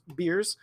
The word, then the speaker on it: Bears: His last name is spelled Bears, by the way, but I feel 100% sure it's pronounced Beers.